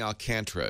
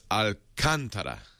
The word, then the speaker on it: Alcantara: Like Alcantara. – Oh, yeah. Boy, did we get tweeted and emailed on that? – I know. – Yep. Well, I know I was saying Alcantara at first. So it's Alcantara.